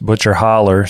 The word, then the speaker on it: Butcher Hollow: Well, she is from Butcher Hollow, which I'm sure is pronounced Butcher Holler.